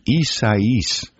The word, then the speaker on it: Isaias: That's Hurricane Isaias.